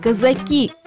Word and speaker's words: казаки: Предпочтительнее вариант «казаки» с ударением на последний слог, за исключением одного единственного случая, когда однозначно нужно говорить «казаки».